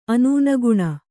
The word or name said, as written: ಅನೂನಗುಣ